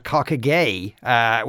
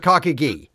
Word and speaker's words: Cockagee: It should be called Cockagay, which I think would constitute a hate crime if it was actually called that. I think it was something to do with the pronunciation in the Irish, but it's called Cockagee. So let's all settle on that one.